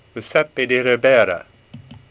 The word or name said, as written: Ribera